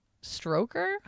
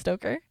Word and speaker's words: Stoker: I thought it was Bram Stoker. Stroker? Wow, my God. That's embarrassing. Okay, it is Stoker. For some reason, I literally Googled Dracula and it said Bram Stoker.